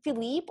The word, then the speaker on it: Philippe: And then Philippe arrives. Is it Philippe or Philip? – It's Philip. It's spelled Philippe. It's a very fancy spelling, but it's just pronounced so Philip.